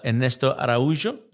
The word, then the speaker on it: Ernesto Araújo: Es el Ernesto Araújo, ¿lo dije bien? – Perfecto.